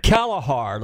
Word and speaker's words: Kalahar: Your buddy is Jim Kalahar.